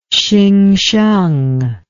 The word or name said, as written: Hsingsheng